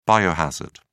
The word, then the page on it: biohazard: British English